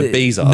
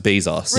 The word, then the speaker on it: Bezos: Like, no, Bezos. – No, let me, it is Bezos. – He's come out and actually said it's pronounced Bezos.